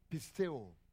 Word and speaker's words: pisteuo: The verb form is derived from the Greek pisteo. Pistis, P-I-S-T-I-S. The verb form is derived from the word pisteo. Pisteo means, it's spelled P-I-S-T-E-U-O.